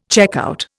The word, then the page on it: checkout: Indian